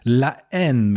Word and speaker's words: La Haine: Im Deutschen heißt der Hass, aber ich finde den französischen Originaltitel dann doch etwas schöner, nämlich La Haine mit stummem H aus dem Jahre 1995.